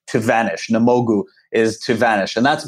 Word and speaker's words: Namogoo: By the way, I'm pronouncing it incorrectly. I guess I say Namogoo, but it's Namogoo.